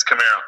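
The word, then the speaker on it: Kamara: This this is bullshit because you can't even say his name right. It's Camaro.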